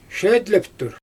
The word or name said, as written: ʃeedleptur